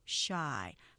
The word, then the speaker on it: shy: 그리고 그러니까 우리가 보통 shy 하고 이음절로 표기하지만 음절 보시면 이름절이죠. shy 하나로 길게 발음하시고요.